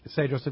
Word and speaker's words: Cedros: It's spelled Cedros Adventures with a C, C-E-D-R-O-S, but it's pronounced Cedros, right? – Correct.